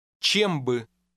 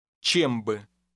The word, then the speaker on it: чем бы: Чем бы? Чем бы? – Only one vowel in that phrase is stressed because the two words are pronounced as one.